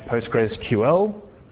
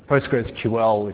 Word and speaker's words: PostgreSQL: The project was renamed PostgresQL. PostgresQL, if anyone even cares, is the correct way to pronounce it, but most people just say Postgres.